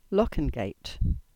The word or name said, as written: Lockengate